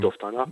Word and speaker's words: Doftana: Er lernt dann 1936 in, wenn ich es richtig ausspreche, Doftana. – In ja, dem, Doftana.